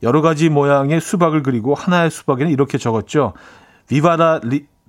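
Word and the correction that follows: Viva la Vida: 비바 라비다.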